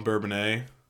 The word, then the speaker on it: Bourbonnais: We stayed in a hotel in Bourbonnais. – Bourbonnais. – Is that what it's called? – Yeah. – Bourbonnais? – Yeah, it's French.